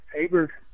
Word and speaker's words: Abert: We have a lake in Oregon right now, Lake Albert, Abert, Lake Abert is how it's said, which has just about disappeared due to irrigation withdrawal.